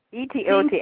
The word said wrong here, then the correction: Otaheite: apples. – What do you call it? – Etiote. – Actually, it's Otaheite.